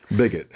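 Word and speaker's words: Begich: It says, Senate Democrats like Mary Landrieu, Gene Shaheen, Mark Pryor, Kay Hagan, and Mark Bigich. Is that how you pronounce his name? – Bigot. – Bigot? Is that right? – Bigot. – Oh, Bigot.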